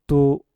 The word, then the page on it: tu: mpur